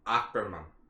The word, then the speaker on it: Ackerman: Ackerman is the way you say it.